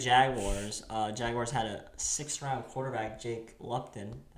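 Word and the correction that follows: Luton: I believe. – Lutton. – Yeah, I heard there was a lot of discussion on how you say his name there. – Yeah, it's Lutton, I think.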